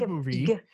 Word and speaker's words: Ghibli: I think Ghibli in Japanese. That's how Miyazaki says it.